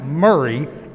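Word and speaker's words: Maury: If a weather person was talking about something happening in a county in Tennessee, for example, that is pronounced Murray and clearly spelled Maori.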